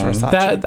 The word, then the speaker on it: Versace: It's pronounced Versace.